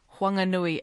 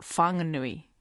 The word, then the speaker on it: Whanganui: Just to make it clear, even though the H is going to be, or is in the name, it's still pronounced Whanganui, it's not Whanganui.